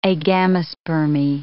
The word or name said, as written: agamospermy